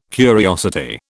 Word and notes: curiosity: British English

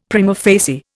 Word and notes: prima facie: Indian

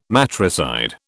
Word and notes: matricide: British English

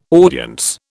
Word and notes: audience: British English

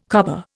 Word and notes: cobber: Indian

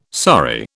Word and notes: sorry: British English

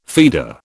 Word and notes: feeder: British English

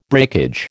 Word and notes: breakage: American English

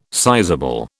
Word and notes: sizable: British English

